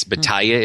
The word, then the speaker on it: Batalla: Batalla is how it's pronounced, B-A-T-A-L-L-A.